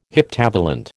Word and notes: heptavalent: American English

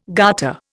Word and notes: garter: Indian

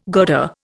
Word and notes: girder: Indian

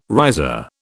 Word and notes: riser: British English

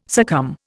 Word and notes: succumb: Indian

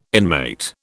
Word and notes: inmate: British English

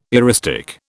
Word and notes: eristic: British English

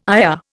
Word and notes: ayah: Indian

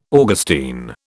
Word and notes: Augustine: British English

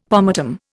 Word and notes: pomatum: Indian

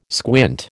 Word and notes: squint: American English

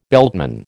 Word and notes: beltman: American English

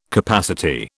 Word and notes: capacity: British English

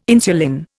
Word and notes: insulin: Indian

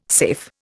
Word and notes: safe: Indian

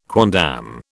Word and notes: quondam: British English

